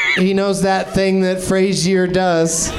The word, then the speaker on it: Fraser: So, no, it's pronounced Frazier. – It's Fraser. – Frazier. – That's like a pet peeve of his. I've seen interviews where he's like, it's not Fraser, it's Frazier.